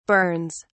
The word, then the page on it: burns: British English